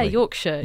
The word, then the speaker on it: Yorkshire: Yeah, Yorkshire.